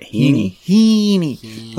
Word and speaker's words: Heaney: Heaney. Like Mr. Feeney?